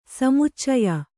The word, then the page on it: ಸಮುಚ್ಚಯ: samuccaya; Kannada